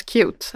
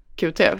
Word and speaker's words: QT: Visst säger cute, vilket jag tycker är väldigt förvirrande. – Ja, men det står här också, uttalas cute. Ja, men så är det, jag har alltid sagt QT.